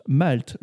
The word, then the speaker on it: malt: Les lettres LT se prononcent Malte. Le mot rime avec alt, non masculin, orge germée utilisée pour la fabrication de la bière.